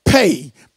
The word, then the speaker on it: pey: And so God wants us to understand something this morning, because in 5780, that word 80 deals with this Hebrew alphabet, pay, P-E-Y, pronounced P-A-Y.